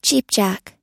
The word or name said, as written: cheapjack